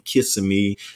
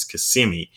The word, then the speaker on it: Kissimmee: And like the joke was that like his mom said something about, oh, that's such a cute name, Kissimmee. And then his sister was like, no, mom, it's not pronounced like that. It's an Indian name. It's Kissimmee.